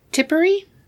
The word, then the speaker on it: Tipperary: So they lived in a cottage near Tipperary. I think is how you say it. Yeah, I know of that.